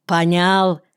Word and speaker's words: понял: «Сидять в степу двое, понял?» Именно так говорили у нас в городе. Не в степи, а в степу, на украинский лад. И слово «понял» с ударением на втором слоге.